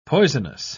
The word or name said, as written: poisonous